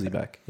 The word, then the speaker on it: Xebec: X-E-B-E-C is a type of ship. – Is that not... Wait. Z? – Well, X-E-B-E-C. Is that how you pronounce it? Zebek, I think it's Zebek. Okay. Zebek, yeah.